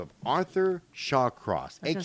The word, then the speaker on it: Shawcross: I thought his name was Sawcross, and I get this whole thing done, and then I start looking about, fuck my life. – Well, and I kept telling you, Shawcross.